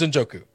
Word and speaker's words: Njoku: How do you say that? – His name is Njoku.